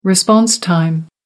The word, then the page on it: response time: American English